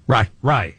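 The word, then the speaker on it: Rai: Yeah, Aaron Rowe. Is that how you say his name? – Rye.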